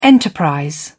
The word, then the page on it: enterprise: British English